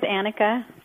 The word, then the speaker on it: Annika: We're going with Annika, seeing as how we're Californians, and it would be pronounced that way by us anyway.